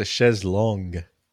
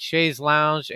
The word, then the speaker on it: chaise longue: It's a chaise longue. – Oh, God. – I knew it. I know you can't try to do an end run on this guy and he tackled you. No, well, yeah, it has both, I guess both pronunciations are appropriate. Chaise lounge and whatever you just said.